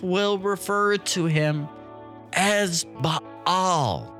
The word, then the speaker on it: Baal: Some people pronounce it Baal, but it's Baal.